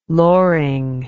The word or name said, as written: loring